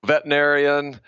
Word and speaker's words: veterinarian: You pronounce veterinarian right. So many people mispronounce it that are in the business for decades. It's not an easy, there's too many syllables. So veterinarian, vet, usually people just go vet, but veterinarian, good job.